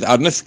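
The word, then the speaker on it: Adonai: but we pronounce it as Adnas, right? Adoin. So the pronunciation is Adnas, ki Hashem anechbad anoyua osele kroise b'kiksavoy, because as we know, you're not allowed to express the name of Hashem in the way it's written.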